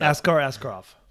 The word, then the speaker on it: Askar Askarov: Askar Asokar, I'm sorry. Did I get his name right or did I screw it up? – Askar Askarov. – Askar Askarov.